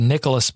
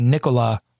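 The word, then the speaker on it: Nicolas: I said Nicholas Batum. It's so easy, but it's actually Nikola Batum. The S is silent. The S is silent. Nikola Batum. Tyler Hansbrough.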